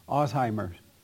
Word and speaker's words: Alzheimer's: Alzheimer's. That's how you say it? – Yeah.